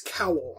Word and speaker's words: Kowal: This is by Mary Robinette Kowal? Kowal? – I think it's Kowal. I think that's how I heard it pronounced on Audible. – K-O-W-A-L.